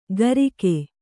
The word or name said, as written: ಗರಿಕೆ